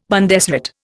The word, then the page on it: Bundesrat: Indian